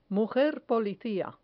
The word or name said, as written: mujer policia